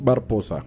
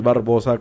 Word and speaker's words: Barposa: Tito Barposa con P. Es que es Barposa. No es Barbosa como lo pronuncias.